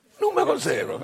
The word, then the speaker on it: Numero Zero: How do I pronounce it? – Numero Zero.